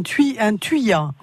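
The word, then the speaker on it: thuya: Annie, elle a un problème avec un tuya.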